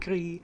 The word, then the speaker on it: Gris: I also played the game Grease, which is spelled G-R-I-S. Not hit the S at the end. – Glee. – Very hard. Yeah, it's more like Gree.